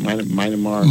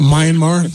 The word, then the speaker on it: Myanmar: Myanmar. – Myanmar, yeah.